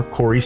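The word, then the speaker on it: Cory: And my first name is spelled C-O-R-Y. I'm at Twitter, Facebook, Instagram, Untapped, all that kind of fun stuff. F-R Corey Stica, last name spelled S-T-I-C-H-A.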